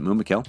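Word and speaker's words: Mûmakil: We're going to pronounce it Mumakil.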